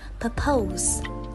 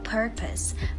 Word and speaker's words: purpose: Mọi người vẫn thường nhấn vào âm hai và đọc là purpose, purpose. Nếu bạn vẫn phát âm danh từ ấy như vậy thì hãy sửa ngay đi nhé, vì cách đọc thực sự của từ này phải là purpose purpose với trọng âm rơi vào âm tiết đầu tiên.